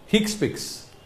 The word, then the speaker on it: HCPCS: It is commonly pronounced as HCPCS.